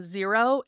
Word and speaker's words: Xero: I use the program Xero. It is spelled X-E-R-O, and that's an actual accounting program.